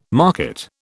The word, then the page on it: market: British English